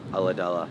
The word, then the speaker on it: Ulladulla: Ulladulla, yeah.